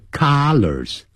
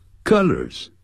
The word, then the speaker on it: colors: 所以 this T-shirt comes in three colors，哎，颜色不要念成 colors，不是啊的音，是 a 的音，肚子 a 的 a， colors colors，那是 C-O-L-L-A-R 加个 S，这是代表多数，叫衣领，领子。